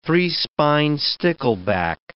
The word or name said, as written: three-spined stickleback